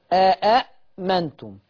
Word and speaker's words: آمنتم: اللي حصل في الهمزة الثالثة إنها أبدلت باتفاق جميع القراء، أبدلت باتفاق جميع القراء، يعني عايز تقول إنها تتنطق كده، آمنتم آمنتم.